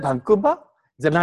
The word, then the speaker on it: バンクーバー: お、 is it バンクーバーじゃないだろ？